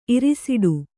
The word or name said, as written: ಇರಿಸಿಡು